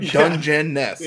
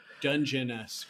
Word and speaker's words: Dungeness: And it's it is spelled right like it sounds, right? Dungeness. – Yeah. Because I was like, did I say it wrong? No, it is dungeon. Dungeness crab.